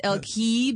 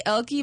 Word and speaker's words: Elke: I pronounce it Elki because of the E. Okay. Because when I say Elki, most understand, Erica?